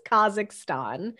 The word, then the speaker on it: Kazakhstan: I used to pronounce that Kanzakistan. And it's Kazakhstan and Brazil.